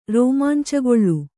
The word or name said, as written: ರೋಮಾಂಚಗೊಳ್ಳು